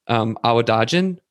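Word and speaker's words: Awadagin: Your husband, Awadajan, correct me if I'm wrong in that pronunciation. I'm horrible with names. – Perfect.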